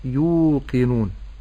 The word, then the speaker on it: يوقنون: هم يوقنون، يو ياء ضمة، يو ياء ضمة مد بالواو، يو قاف كسرة، قي يوقي، ولا أقول يوكي، نون ضمة نو، نون ضمة مد بالواو، أقول نون. النطق السليم يوقنون يوقنون. – وانتبهوا ألا أمد في القاف فأقول يوقنون فهذا خطأ، ولكن أقول يوقنون.